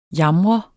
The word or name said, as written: jamre